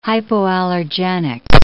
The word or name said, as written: hypoallergenic